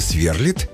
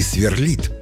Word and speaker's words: сверлит: Где там ударение поставить? Раскроем секрет. Он и сверлит, и сверлит.